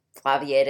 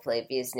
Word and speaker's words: Flavia: Yep, we are pronouncing Flavia's name wrong. I'm sorry, Flavia. – But, like, it's just, like, a British thing, like, an English thing. – It's supposed to be Flavia, but it's...